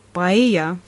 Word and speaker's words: Paella: mina õpetan Tartu Ülikooli tõlkekeskuses suulist tõlget ja mul on väga hea meel, et meie Paella Ekspressi hispaaniakeelsed intervjuud aitavad teieni tuua Tartu Ülikooli suulise tõlke magistrandid. – peame vist selgitama, miks saate nimi on Paella Ekspress. – Paella või nagu eestlased vahel hääldavad, paella on Hispaania toit, mida teatakse terves maailmas.